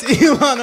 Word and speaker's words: Ilhan: Uh-oh. Ilio Omar. How do I say that? – It's Ilhan Omar and Jesse Waters.